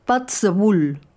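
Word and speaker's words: Puirt à beul: La misma idea, pero en gaélico, una lengua coexistente con el inglés en Escocia, Pat the Bull, que se escribe Puit a Beul, melodías de boca.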